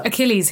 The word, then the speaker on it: Achilles: How do you say that? – The Achilles heel.